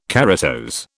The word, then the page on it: keratose: British English